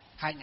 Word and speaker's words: hạnh: Hạnh ở đây tức là gọi là hành. Chữ hành nguyên đó, chữ Hán là chữ hành, nhưng đọc là gì? Là hạnh.